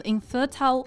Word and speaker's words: infertile: For example, sperm donation makes a huge impact on the lives of infertile couples and families of all kinds that want to conceive a child.